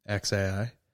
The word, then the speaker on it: xAI: It's just X, like the letter X, then AI, like how you would say A, then I, XAI.